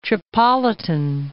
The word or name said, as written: Tripolitan